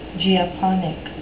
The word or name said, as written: geoponic